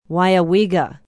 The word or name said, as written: Weyauwega